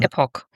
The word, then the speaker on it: Epoch: Was aber nicht schlimm ist, weil der One-Shot-Manga, den wir besprechen, mit dem Titel One Piece X Dragon Ball Cross Epoch, Epoch oder Epoch? Wie wird es auf Englisch ausgesprochen? – Epoch. – Epoch, meine ich doch.